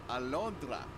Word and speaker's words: Alondra: So tell me how to say your name again. – Alondra. – Alondra. – Yeah, oh, just like that. Yeah, you did. – Yay! – He did the R.